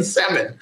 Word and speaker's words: salmon: No, you don't even pronounce the L in salmon.